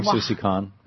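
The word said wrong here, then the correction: Aung San Suu Kyi: Aung Suu Kyi.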